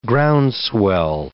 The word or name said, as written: groundswell